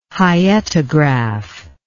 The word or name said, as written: hyetograph